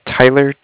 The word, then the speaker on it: Tyler: Yeah, my name is Tyler, T-Y-L-E-R, and I'm calling with Cardiff. It's spelled C-A-R-D-I-F-F, as in Frank.